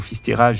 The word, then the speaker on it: Fisterra: Après, oui, donc, c'est ce que j'ai mal prononcé tout à l'heure. – Et après, une remontée de Cabo Fisterra jusqu'au Cap Finistère français, en Bretagne.